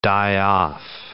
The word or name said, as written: die-off